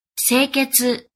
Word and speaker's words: Seiketsu: It's an organization method that uses 5 Japanese words that I'm going to mispronounce here. Seri. – Seri. – Seiton. – Seiton. – Seiso. – Seiso. – Seketsu. – Seiketsu. – And Shitsuki. – Shitsuke.